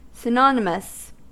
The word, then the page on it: synonymous: American English